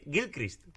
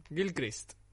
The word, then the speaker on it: Gilchrist: se han dado cuenta que a lo que aspiran es al puto Kid Grislich. – Kid Gilchrist. Gilchrist.